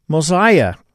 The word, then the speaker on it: Mosiah: The Book of Mosiah, as we pronounce it.